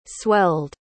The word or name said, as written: swelled